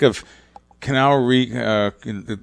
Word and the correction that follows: Keanu: Matrix guy, Canal Reeves, right? Am I saying his name right? Keanu Reeves? – Keanu Reeves. – Keanu. That's the way you say Keanu.